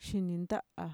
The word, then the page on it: shininta'a: Mixtec